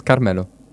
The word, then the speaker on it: Carmelo: Because I say like Carmelo, but I don't think. – Yeah, it's the same. It's Carmelo.